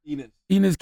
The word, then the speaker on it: Enes: Enes? – Enes Kanter.